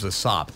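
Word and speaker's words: A$AP: His name's Asop. That's how he pronounces it.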